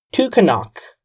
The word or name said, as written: took a knock